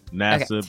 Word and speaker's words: Nassib: Let me ask you about another sports story, and that's Carl Nassib, right? Is that how you pronounce his last name? – Yeah. Nassib, okay. Nassib.